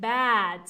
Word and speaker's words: bad: So those are pronounced differently. – Bed is what I sleep in. Bed is what I sleep in. And bad is when I'm doing something bad or I feel bad. So these two are actually pronounced differently.